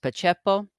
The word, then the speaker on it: Pacepa: Uh, I think his, his name is pronounced Pacepa. Perhaps you've heard of him.